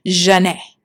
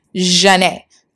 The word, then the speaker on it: Zhané: So Renee is like, okay, let's combine our first names, and we'll use, like, the French pronunciation of them, which is Jah-Nay. Jah-Nay. So they added a Z for a little pizzazz, a little spice, you know, and that's how we got Jah-Nay.